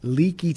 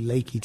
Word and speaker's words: Leakey: And as always, I don't know if it's raining in San Antonio, but in Leakey, Texas, it's actually Lakey, Texas, but it's spelled Leakey.